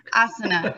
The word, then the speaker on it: asana: Um, I do find asana, is that how you say it? Okay, yeah, asana.